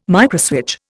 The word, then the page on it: microswitch: Indian